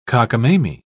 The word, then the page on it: cockamamy: British English